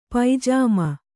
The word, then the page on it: ಪೈಜಾಮ: paijāma